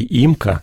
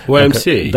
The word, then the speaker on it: YMCA: Я всегда читал это по-русски «имка». Вот, только недавно мне сказали, что это... – YMCA.